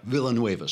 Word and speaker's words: Villanueva: One of them comes out, Alejandro Villanueva, who is one of, is that how you pronounce it? Villanueva? – Villanueva, sorry.